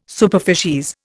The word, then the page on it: superficies: Indian